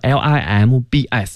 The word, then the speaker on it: limb: 四肢那个词呢，limbs 那个 b 不发音，就是 limb。